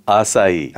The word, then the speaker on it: acai: A-C-A-I. – It's acai.